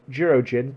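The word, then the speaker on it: Jurōjin: You pronounced it right. The way it's written is Jirojin.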